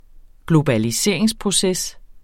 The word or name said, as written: globaliseringsproces